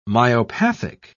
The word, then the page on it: myopathic: British English